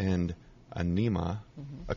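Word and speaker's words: Ænima: Anima? – Anima.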